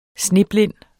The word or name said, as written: sneblind